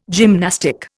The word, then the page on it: gymnastic: Indian